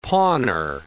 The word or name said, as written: pawner